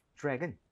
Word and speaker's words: drag: 그리고 발음할 때 드래그가 아니라 TR 모음은 그때 치읓 비슷했죠. DR 모음은 Drag. – 지읒 비슷합니다.